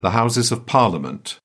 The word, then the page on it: Houses of Parliament: British English